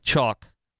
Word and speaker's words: Choq: Thankfully, the Patriots at Chalk, spelled C-H-O-Q, pronounced chalk, but C-H-O-Q.